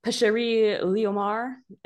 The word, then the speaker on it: Pêcherie Léomar: It showed boxes bearing the name, oh, I'm going to butcher this, maybe somebody else's French is better, Pêcherie Léomar. – I think that's right.